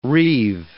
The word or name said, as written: reive